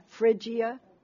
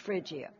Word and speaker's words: Phrygia: It's P-H-R-Y, Phrygia, G-I-A. It's pronounced Phrygia.